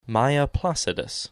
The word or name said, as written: Miaplasidus